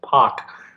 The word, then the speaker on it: Pak: It's so funny, he actually pronounces it Pak.